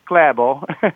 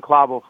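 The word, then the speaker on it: Klæbo: Yeah, so Johannes Hoslo Klabo, it's actually pronounced Klabo, but it's, you know, we've settled on Klabo for purposes of broadcasting.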